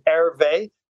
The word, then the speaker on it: Hervé: It's my phonetic name, my name on the metaverse, which is A I R V E Y, Hervé.